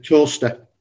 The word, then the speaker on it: Towcester: And for 2022, we're looking at a three-day festival in Whittlebury Park, again, in Towcester. Is that how you pronounce that word? – Towcester.